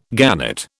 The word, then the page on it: gannet: British English